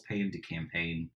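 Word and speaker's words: pain de campagne: Uh, so in flour, water, salt, yeast, my go-to is uh, a recipe that Forkish calls "Pan to Campaign," which I'm sure I'm saying that wrong, uh, but it's a, oh, you nailed it.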